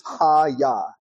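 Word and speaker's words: ha-yah (הָיָה): Let there be in Hebrew is ha-yah, is how you pronounce it.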